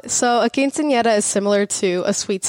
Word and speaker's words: quinceañera: I was talking with your mother before, and we had talked about your quinceañera, which I know I'm going to get railed over for pronouncing like a typical white person. – No, it was fine. It's quinceañera. – Can you pronounce it for me? – Yeah, quinceañera. – I can't do that, but okay.